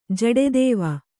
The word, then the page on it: ಜಡೆದೇವ: Kannada